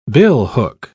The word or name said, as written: billhook